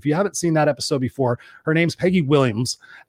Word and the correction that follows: Willms: and Wilms. Got to make sure we say that correctly. W-I-L-L-M-S, Wilms, Peggy Wilms.